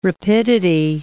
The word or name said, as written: rapidity